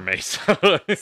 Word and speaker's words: Mesa: Why do I always say Mesa? I always say it wrong. – For sure, Mesa.